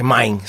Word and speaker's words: Mein: É mine. Isso é muito é, em, mine.